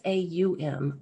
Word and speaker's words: Aum: And, you know, even in the Om, um, the Hindu tradition of Om is not O M, it's A U M, Aum, you know, and whenever you mm-hmm. – put it together, it comes out Om. – Mm-hmm. – And it's four syllables, Aum, and silence.